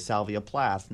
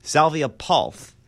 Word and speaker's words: Salvia Palth: Palth, sorry, I have been saying his name wrong this entire time. I've thought it was Salvia Plath. Nope. Salvia Palth.